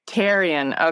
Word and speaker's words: Therrien: You can drop the H. It's Therrien. – Therrien.